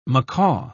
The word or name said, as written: macaw